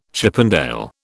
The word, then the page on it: Chippendale: British English